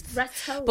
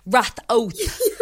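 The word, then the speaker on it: Ratoath: So it's called Retoath. – Retoath. – But most... Retoath, yeah, but most people call it Rathoath, like with the double H, Rathoath.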